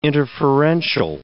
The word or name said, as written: interferential